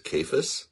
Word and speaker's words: Cephas: You may also see him referred to as Cephas, which is spelled C-E-P-H-A-S.